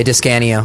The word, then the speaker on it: DiScanio: It's okay, DiScanio.